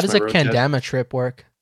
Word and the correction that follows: Kendama: Kendama. – Kendama. Okay. Ken. You know, Ken. Like Ken. Kenny.